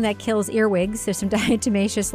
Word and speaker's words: diatomaceous: Diatomaceous, earth food grade, 10 pounds. – I don't understand. – Okay, first of all, I actually know how to pronounce that. It's a diatomaceous.